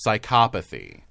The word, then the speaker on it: psychopathy: And I've checked the pronunciation, so don't you dare step to me. – I was curious about this. – Psychopathy. – That's what I thought.